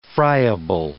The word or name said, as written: friable